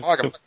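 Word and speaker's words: Maugham: Somerset Mogum.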